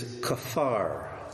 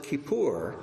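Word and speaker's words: kafar (כפר): The third word is kafar. Kafar. Say kafar. Okay, good children. All right. This is also a verb, but you could pronounce it kippur, and it becomes a noun, like in Yom Kippur.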